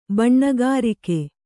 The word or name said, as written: ಬೆಣ್ಣೆಗರಗ